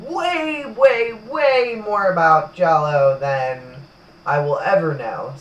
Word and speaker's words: giallo: Giallo! – Giallo. – Giallo. – Giallo. – Giallo. Do we have a translation? – That's my understanding of how it's pronounced from our Italian French.